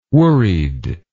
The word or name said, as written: worried